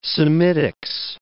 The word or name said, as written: Semitics